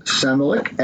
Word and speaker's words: Samolyk: And the plaintiff, Samolik, S-A-M-O-L-Y-K, the case is Samolik v. Berthe, B-E-R-T-H-E,